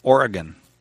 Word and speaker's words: Oregon: Kevin in Portland, Oregon.